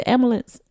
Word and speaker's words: ambulance: So is it ambulance? Ambulance. – Ambulance. – That's the correct right pronunciation. – Ambulance. – Okay. – Some people stick the P in there. Ambulance.